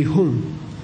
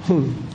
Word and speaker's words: hum: HUM. Okay? By the way, it's not hum or hum. It ends with an NG. Say hum. Hum. Okay. Everybody mispronounces that.